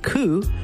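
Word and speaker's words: ku (九): So in Japanese, the number 9 is pronounced ku, which is the same sound a pigeon makes, like a cooing sound. So it's almost like bird ku, bird ku, right?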